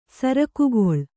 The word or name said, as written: ಸರಕುಗೊಳ್ಳು